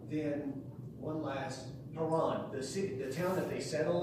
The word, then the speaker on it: Haran: Haran, the brother of Abraham. The H is like our H in English.